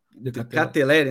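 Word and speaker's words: De Ketelaere: Decateleri, que tá. – decatelere né? Tá certo, né? Tá certo, tá, tá a pronúncia.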